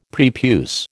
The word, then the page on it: prepuce: American English